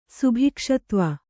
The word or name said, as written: ಸುಭಿಕ್ಷತ್ವ